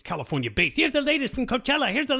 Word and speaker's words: Coachella: No, it's Coachella. I like to mispronounce it like Tijuana.